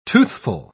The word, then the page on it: toothful: British English